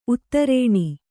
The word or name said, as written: ಉತ್ತರಣೆ